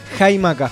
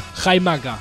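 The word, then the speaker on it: Xaymaca: Vamos con la pronunciación. ¿Cómo sería? – Jaimaca. – Jaimaca, ahí está. Jaimaca sería la pronunciación correcta de cómo los pueblos originarios mencionaban a lo que hoy conocemos como Jamaica.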